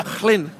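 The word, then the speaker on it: Llŷn: I said, "Well, I've got a cousin who lives on the Chlin Peninsula." He went, your pronunciation is incorrect. It's Chlin.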